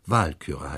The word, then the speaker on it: Walküre: Ich sage gleich mal in Parenthese, die germanische Anfangsbetonung fordert auch im Deutschen, dass die Namen auf der ersten Silbe betont werden, auch wenn es sich nicht nur um zweisilbige, sondern um dreisilbige Namen handelt. Also Burgunder heißt es, Nibelungen heißt es, Walküre heißt es und nicht, ich verweigere mich der falschen Betonung auf der falschen Silbe.